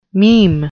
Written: meme

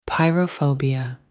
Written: pyrophobia

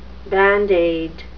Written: band-aid

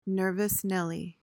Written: nervous nelly

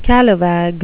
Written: scalawag

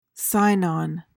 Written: Sinon

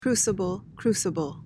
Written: crucible

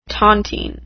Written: tontine

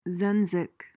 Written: zenzic

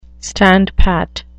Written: standpat

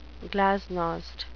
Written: glasnost

